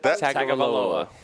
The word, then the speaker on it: Tagovailoa: Tagawala, is that how you say it? – Taulia Tagovailoa.